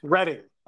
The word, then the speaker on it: Reading: Is it Reading or Reading? It's Reading, I think. – Reading.